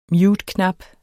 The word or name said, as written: muteknap